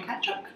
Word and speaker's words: Tkachuk: My name is Georgia Kachuk. Don't assume you know how to spell that. It's Georgia, T-K-A-C-H-U-K.